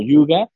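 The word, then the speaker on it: hygge: En realidad se escribe H-I-G-E. Estoy seguro que lo estoy pronunciando mal, pero bueno, al principio del libro, al menos ellos dicen que este término se, se pronuncia más o menos como Yuga Dice, descubre el arte danés del bienestar y la felicidad, escrito por Bárbara Hayden.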